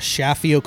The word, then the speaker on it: Chaffiot: You guys need to head on over to Chafio Collection. It's spelled Chaffiot. I believe it's French. I believe. I could be wrong, but the IOT, I think, is French.